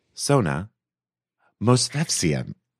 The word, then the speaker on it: Movsesian: Dearest guest, Sona, Movsesian. Movsesian.